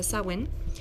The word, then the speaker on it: Samhain: Normalmente em, en la Wicca el año nuevo de nosotros es Samhain o Samhain, como lo conocen muchísimos, pero se pronuncia Samhain.